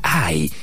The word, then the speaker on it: Hai: El nombre de la ciudad se pronuncia Ai y se escribe H-A-I.